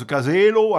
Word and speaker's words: casella: Alors le terme de caselle, qui s'écrit casella en occitan graphié selon les normes de l'Institut d'études occitanes, qui se prononce casello, en français caselle, qu'est-ce que ça veut dire ?